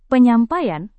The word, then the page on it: penyampaian: Indonesian